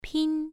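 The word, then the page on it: pin: tone 1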